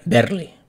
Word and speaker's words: barely: Me chiva Garayo que la palabra Barely se pronuncia Berly.